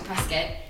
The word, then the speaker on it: Prescott: And so I finally went to Prescott, which is, it's pronounced Prescott, not Prescott, for years.